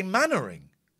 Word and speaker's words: Mainwaring: Mannering is a strange name because it's spelt Mainwaring. And we say Mannering.